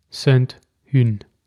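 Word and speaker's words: Sainte Hune: Aber der schreibt nicht Rosacker Grand Cru auf seine Flasche, sondern Clos Saint-Hün. Hün. Das H spricht man ja nicht, habe ich mir sagen lassen.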